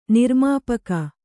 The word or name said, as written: ನಿರ್ಮಾಪಕಿ